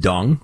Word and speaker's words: Deng: Number one, Deng is pronounced dung, which brought me great delight during his entire reign.